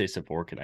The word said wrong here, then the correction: Skovran: I butchered that, man. Skovran.